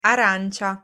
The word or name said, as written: arancia